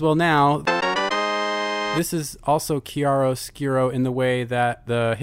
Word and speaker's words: chiaroscuro: Yeah, well, I mean, Martha had its, like, very, you know, naturalistic lighting theme, where this is all about chiaroscuro. – Close. – Say it for me. – Well, now I'm in my head. Chiaroscuro. – Chiaroscuro? – Yeah, I'm pretty sure that's how you say it.